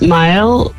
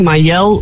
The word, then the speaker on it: Mael: Ja, onze dochter die noemt Mael. En iedereen zegt Mael of Mael. En dat is echt verschrikkelijk.